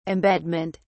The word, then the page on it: embedment: British English